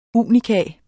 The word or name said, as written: unika